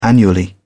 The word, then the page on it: annually: British English